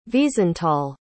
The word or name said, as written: wiesenthal